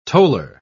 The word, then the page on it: toller: British English